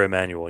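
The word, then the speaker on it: Emanuel: Emmanuel, right? Is that how you pronounce yeah. – it? – Yeah, R. Emmanuel, yep.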